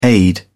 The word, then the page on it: aide: British English